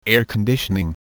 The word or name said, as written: air conditioning